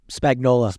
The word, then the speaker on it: Spagnuolo: Spagnola.